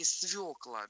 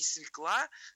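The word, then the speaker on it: свёкла: Вот, поэтому здесь природа ошибки понятна, да, ну, конечно, надо запомнить, что все-таки свекла, да, никакая не свекла, вот, а свекла.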